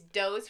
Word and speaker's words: Do's: It's Do's. But it's spelled like D-O, but it's Doe. It's Doe's Final Exit, where he spoke of mass suicide and, quote, the only way to evacuate this earth.